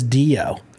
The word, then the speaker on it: Dio: And so, yeah, but Dio. He says Dio. – Dio sounds like Dino.